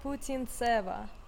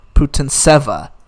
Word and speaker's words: Putintseva: Hi, my name is Yulia Putintseva. Putintseva. – Okay, so Putintseva beat Risk Arbitrage.